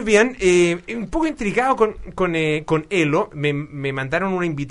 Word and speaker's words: Ello: Se escribe Ello, es con doble L, Ello, pero se dice Elo, para conversar acerca de esta nueva red social, qué es lo que significa.